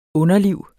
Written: underliv

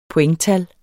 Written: pointtal

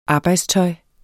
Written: arbejdstøj

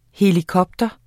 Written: helikopter

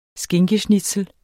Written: skinkeschnitzel

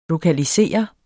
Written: lokalisere

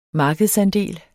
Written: markedsandel

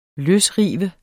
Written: løsrive

